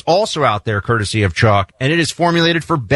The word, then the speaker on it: Choq: You really need it from our friends at Chalk. Chalk makes incredible supplements. It's spelled C-H-O-Q.